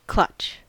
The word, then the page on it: clutch: American English